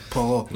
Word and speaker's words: Poirot: It's Poirot.